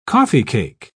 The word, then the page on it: coffeecake: British English